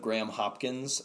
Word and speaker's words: Graham Hopkins: There was a kid in high school that misheard the pronunciation of my name, and instead of Graham Hopkins, thought it was Grandma Napkins.